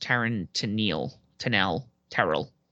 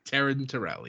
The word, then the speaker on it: Taryn Terrell: And then Camille retained her women's title against Taryn Terrell. Totally butchered that. – Taryn Terrell, yes.